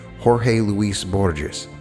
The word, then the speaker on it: Jorge Luis Borges: If I pronounce the Spanish correctly, it's Jorge Luis Borges. For those who speak Spanish, it's J-O-R-G-E, and then Luis, L-U-I-S, and Borges, B-O-R-G-E-S, who departed this world only recently.